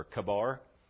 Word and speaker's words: Chebar: And so as Ezekiel sees this, he's down by the river, you might have said Chebar. I looked at it after hearing some different ones pronounce it on YouTube, and it's actually pronounced with a K. It's supposed to be Chebar or Kabar.